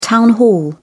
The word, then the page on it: town hall: British English